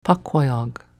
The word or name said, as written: Paquayag